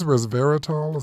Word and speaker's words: resveratrol: Is this resveratrol? Is that how it's pronounced? – Yes, that is.